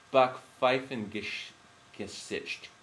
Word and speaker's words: Backpfeifengesicht: Backpfeifengeist. – Oh, oh, no, it's Backpfeifengesicht.